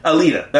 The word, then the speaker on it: Alita: Alita, Or is that how you say it? How do you say it? Alita. Alita. That's it.